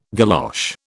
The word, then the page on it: galosh: British English